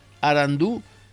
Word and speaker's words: Arandú: Arandú, con acento en la U final, aunque la grafía guaraní no lo marca, significa inteligente o sabio.